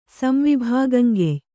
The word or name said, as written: ಸಂವಿಭಾಗಂಗೆಯ್